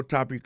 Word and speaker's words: topic: Alors mot topic là qui signifie sujet, ça veut dire accent on éclate tombe sou premier syllabe là.